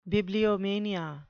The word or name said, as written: bibliomania